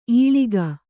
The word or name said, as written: ಇಳಿಕು